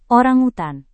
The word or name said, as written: orangutan